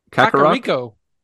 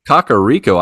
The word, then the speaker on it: Kakariko: Kuroka? Kakariko. Kakariko. I left out a few syllables there.